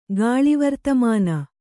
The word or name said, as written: ಗಾಳಿವರ್ತಮಾನ